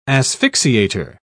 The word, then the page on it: asphyxiator: British English